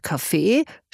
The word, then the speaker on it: Kaffee: Das Wort Kaffee spricht er selbstverständlich mit Betonung auf dem zweiten Vokal aus.